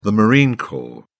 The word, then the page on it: marine corps: British English